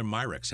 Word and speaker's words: Myrick: Spelled Myricks, so it certainly looks like Myrick, and we just say Myrick.